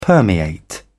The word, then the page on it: permeate: British English